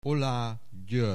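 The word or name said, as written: Oláh György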